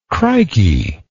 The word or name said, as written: crikey